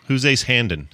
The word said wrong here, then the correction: Hanlon: A lot of people say his name wrong, and I don't know why. To me, it's something that's Hanlon? It's burned into my head.